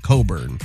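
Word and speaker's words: Cockburn: Because it's a last name, but they pronounce it Coburn.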